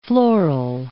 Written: floral

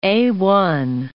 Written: A1

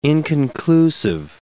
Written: inconclusive

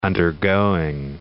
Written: undergoing

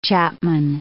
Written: chapman

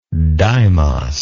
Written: deimos

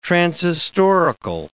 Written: transhistorical

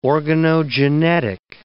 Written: organogenetic

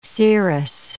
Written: cerous

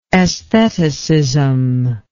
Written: estheticism

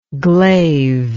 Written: glave

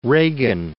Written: Reagan

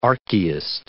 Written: archaist

